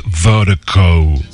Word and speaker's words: Virdiko: And Vertigo is spelled V-I-R-D-I-K-O. That's V-I-R-D-I-K-O, pronounced Vertigo.